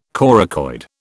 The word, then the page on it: coracoid: British English